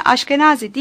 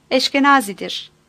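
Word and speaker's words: Eshkenazi: Dalya'nın aile ismi Aşkenazi değil, Eşkenazi'dir. Birçok Musevi okuyucu için bu ne kadar garip görünse de Bulgarlar için bu olağandı. Ve Dalya babasının ismini İngilizce'de her zaman bu şekilde telaffuz ettiğini söylemişti.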